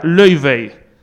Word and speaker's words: Laufey: Se escribe Laufey, su nombre, pero se pronuncia Löyvey. Cosas de los islandeses.